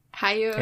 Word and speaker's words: Hayao: Hayao Miyazaki.